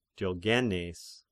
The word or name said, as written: Diogneses